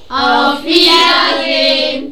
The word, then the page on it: auf Wiedersehen: german